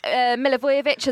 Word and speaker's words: Milivojevic: I think Milivojevic as well, he got some valuable game time.